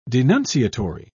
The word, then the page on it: denunciatory: British English